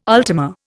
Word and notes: ultima: Indian

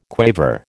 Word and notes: quaver: American English

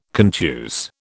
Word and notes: contuse: British English

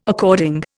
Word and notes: according: Indian

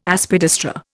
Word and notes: Aspidistra: Indian

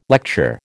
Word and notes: lecture: American English

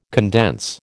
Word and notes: condense: American English

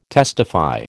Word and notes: testify: American English

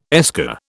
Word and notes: esker: British English

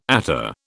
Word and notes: attar: British English

